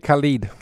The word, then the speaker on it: Khaled: DJ Khaled. – Khaled.